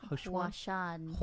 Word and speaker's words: Huashan: I thought it was H-U-A-S-H-A-N. – How do you say that? Huan? – Huashan. – Huashan. – H-U-A-S-H-A-N.